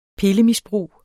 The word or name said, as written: pillemisbrug